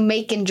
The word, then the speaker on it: Macon: Macon? Uh-huh. Well, that sounds weird, but I guess it's like bacon. – That's Macon.